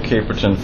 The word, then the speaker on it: Caperton: My last name is spelled E-M-B-E-R-S-I-T. – Dave Caperton, Federal Reserve Board, C-A-P-E-R-T-O-N. – Stephen Mintz, M-I-N-T-Z, staff of the FCIC.